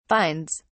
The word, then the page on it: finds: British English